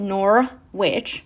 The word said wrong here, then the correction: Norwich: But I was corrected all the time. Norwich, you mean Norwich, you stupid American.